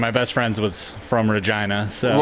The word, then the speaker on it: Regina: They didn't believe us that how you pronounce that name is Regina.